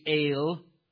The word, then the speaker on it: El: The word El here, and because of the ending on it, the vowel has changed at the beginning, but it would be El. That's a long E vowel, A, El.